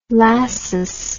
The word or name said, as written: Lassus